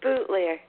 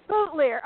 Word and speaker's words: Boutilier: Is it Boutilier? – It's Bootlier. Bootlier. Oh, so close.